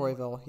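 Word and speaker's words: Royval: Royville, yep.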